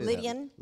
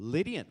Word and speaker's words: Lydian: How do you say Lydian. – That? Lydian. Ooh. Lydian kingdom.